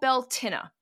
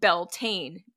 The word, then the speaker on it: Beltane: It's called Beltinna, though we usually just pronounce it phonetically nowadays, and that's apparently acceptable. We call it Beltane, B-E-L-T-A-N-E, Beltane. It's more commonly known around the world as May Day, celebrated on May 1st.